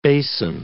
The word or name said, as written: basin